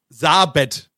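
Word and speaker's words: Sabeth: Wie wird sie ausgesprochen? Ich weiß nicht. Sabet, ja, so. Sabet, okay.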